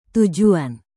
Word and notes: tujuan: indonesian

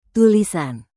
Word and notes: tulisan: indonesian

